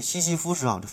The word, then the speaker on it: 西西弗斯: 然后啊，对西西弗斯啊，这发音不准，西是东西南北的西哈，西西东西南北的西，福呢是，呃，佛教的佛，没有达林儿啊，福啊，西西弗斯是呃斯大林的斯啊，斯，F M 就是英文字母 F M 啊，收音机的 F M 嘛，西西弗斯 F M 啊。